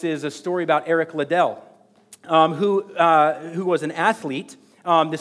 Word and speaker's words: Liddell: Eric Liddell or Little? How do we say it? Eric Liddell.